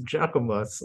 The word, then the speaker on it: Jacquemus: Is it Jacquemus? Is how you say it? – Jacquemus.